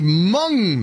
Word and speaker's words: Hmong: The governor refused to back down from his vote to pardon a Hmong man." Hmong is, it's not Hmong, but it's spelled Hmong. That's the community that has largely populated Minnesota.